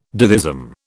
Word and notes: ditheism: British English

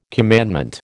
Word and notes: commandment: American English

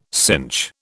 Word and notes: cinch: British English

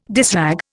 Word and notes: dishrag: Indian

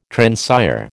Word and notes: transire: American English

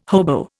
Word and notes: hobo: Indian

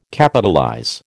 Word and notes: Capitalize: American English

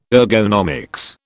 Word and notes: ergonomics: British English

